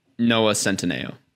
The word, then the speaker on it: Noah Centineo: Noah Centineo.